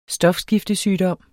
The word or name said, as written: stofskiftesygdom